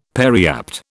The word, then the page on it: periapt: British English